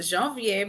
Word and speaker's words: Janvier: She is Miss Martha Janvier. Is that right? Did I say it right? – Yes, Janvier, but Janvier.